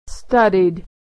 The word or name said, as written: studied